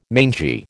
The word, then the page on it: mangy: American English